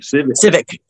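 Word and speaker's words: Sivec: I can't know how to pronounce her last name. It's Tara Civic. – Civic. Yep.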